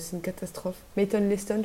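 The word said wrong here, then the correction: Leighton Meester: je crois, un truc comme ça. – Oui, Layton Mister. – Layton Leston, j'arrive jamais à prononcer son nom, c'est trop bizarre.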